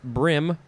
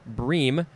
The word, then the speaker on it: bream: It could be pronounced a couple ways, brim or bream, B-R-E-A-M.